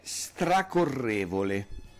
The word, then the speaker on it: stracorrevole: Allora, una di queste parole, per esempio, è stracorrevole. – Okay. – Con l'accento sulla E, stracorrevole, okay? – Stracorrevole.